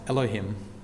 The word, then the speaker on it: Elohim: And in the Hebrew language, this word is pronounced Elohim.